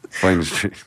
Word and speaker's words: Vignes: And he still lives on in Vignes Street. – That's correct. Vine Street. – our lovely way of pronouncing, yeah, massacring his name, yes.